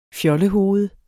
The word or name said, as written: FJOLLEHOVEDER